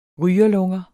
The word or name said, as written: rygerlunger